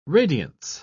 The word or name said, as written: radiance